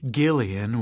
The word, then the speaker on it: Gillian: of which Gillian with a hard G was supposedly an example.